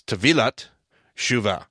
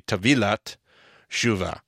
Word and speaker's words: Tavilah T'Shuvah: But apparently, the correct pronunciation of this is Tavilah T'Shuvah. You take the T of the Shuvah, and you bring it over into the Tavilah, and you put your emphasis on the V and not the T, because I always go Tavilah. It's supposed to be Tavilah T'Shuvah.